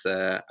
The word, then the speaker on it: Lebedev: It's Lebedev.